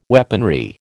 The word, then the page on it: weaponry: American English